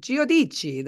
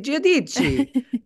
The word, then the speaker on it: Giudice: So it's so funny when I would see her name, and then when I watched the show, I was like, Giudice, that should be Giudice, is how that should be pronounced, but it's Giudice.